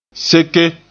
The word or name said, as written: seke